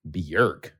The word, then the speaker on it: Björk: Not how you say her name at all. – Really? – It's Björk. – Björk. – Björk. – Mm. That must be the accent, though, right? It's the Icelandic pronunciation of her name. It's B-J-O-R-K. People think it's Björk.